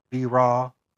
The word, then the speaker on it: B-Raw: And then I have my separate artist page on Facebook, which goes under B-Raw, which is spelled B as in boy, dash, and then Raw, R-A-W, on Facebook.